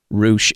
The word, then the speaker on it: Ruesch: The name you're looking for there is Heather Ruesch, and it is spelled, Jeff, correct me if I'm wrong, it is R-U-E-S-C-H, pronounced Ruesch.